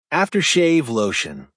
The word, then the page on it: after shave lotion: American English